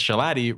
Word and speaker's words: Shalotti: A pro tip from Ben Shalotti, because Shalotti rhymes with Patty.